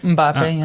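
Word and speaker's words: Mbappé: But, I mean, he's playing in the same league as, you know, Mbappe. Is that how you say it, right? – Mbappe, yeah.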